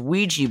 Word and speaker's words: Ouija: It's almost like Ouija boards is what I called it. I found out, correction, it's Ouija boards obviously ghost hunters are idiots because they spelled it Ouija. It's spelled O-U-I-J-A. How does, there's no W in there. How is it Ouija?